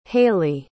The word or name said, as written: hayley